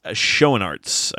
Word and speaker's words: Schoenaerts: Matthias Schoenaerts, I believe, is the American pronunciation of it.